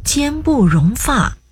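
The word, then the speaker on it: 间不容发: 一样啊，正确读音是坚不容发。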